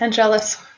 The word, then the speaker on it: Angelus: And we pronounce it Angelus because that's the way Joss wanted it, and he corrects his actors.